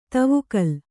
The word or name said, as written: ದೇವಕುಲ